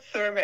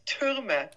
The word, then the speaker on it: Thuermer: Thanks for having me, but you have to practice my last name. It's not Thurmer, it's Thurmer. – Thurmer. – TH is pronounced differently in Germany.